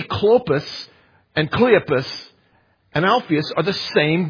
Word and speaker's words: Cleopas: Well, if you were to go into the King James Version, adds an E, the letter E, to this man's name, Clopas, to the name Cleopas. You'd have to pronounce it different because it's got an extra vowel in it.